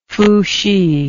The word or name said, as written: fuhsi